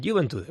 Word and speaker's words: Juventude: El Juventude.